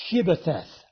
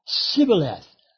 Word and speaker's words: Shibboleth: If he said no, then they would say to him, then say Shibboleth. And he would say Sibboleth, for he could not pronounce it right. So it was a test. They knew that the Ephraimites had a difficulty in pronunciation of the SH sound. They could say it Sibboleth, which means, by the way, river or stream, but they couldn't say Shibboleth.